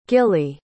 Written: gilli

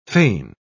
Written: feign

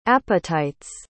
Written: apatites

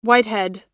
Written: whitehead

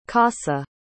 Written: corsa

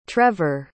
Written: trever

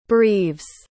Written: breves

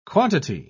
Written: quantity

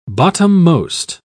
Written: bottommost

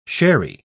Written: sherry

